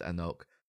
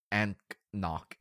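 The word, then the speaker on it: anCnoc: But it's pronounced Anok. – It looks like it's A-N-C-N-O-C. – Yeah. It's more like Anknock. Anknock. Anok.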